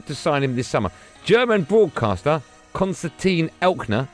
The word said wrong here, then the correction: Eckner: is that how you say his name, producer Tom? – Eckner. – So I've got the first bit right? – Yeah.